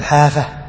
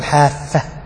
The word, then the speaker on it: حافة: فيه عدة كلمات مثل حافة، لا تقول حافة، إنما هي حافة بالتخفيف.